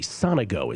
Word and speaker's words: Sonego: Lorenzo Sonego, Sonego is how you pronounce it. Lorenzo Sonego, I am taking at plus, or I got him at plus 158 versus Hugo Humbert. You can find Sonego at plus 167.